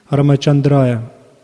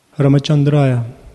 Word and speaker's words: Рамачандрая: Эта мантра «Ом намо Бхагавате Рамачандрая» так и произносится, как я произнес. Рамачандрая. Рамачандрая.